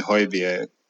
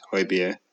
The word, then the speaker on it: Højbjerg: So, one we knew we were going to get in Højbjerg. That's the way you pronounce his name. He said Højbjerg.